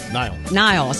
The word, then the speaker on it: Niall: Neil, and I say this wrong. Is it Neil? – Niall.